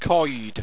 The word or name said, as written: qoide